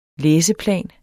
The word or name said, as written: læseplan